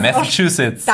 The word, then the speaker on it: Massachusetts: Massachusetts.